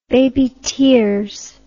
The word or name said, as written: baby tears